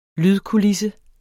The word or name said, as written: lydkulisse